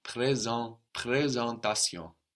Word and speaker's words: présentation: And then we have S-E-N. This S sounds like a Z, like in English, presentation. But we have E-N. We have an N on the end of a syllable. So that's going to be sucked into the E, which changes the sound.